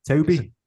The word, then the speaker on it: Tobe: Toby. – It is Toby, because it's spelt Tobe. Normally we see Toby with a Y, but he's got an E on the end of it.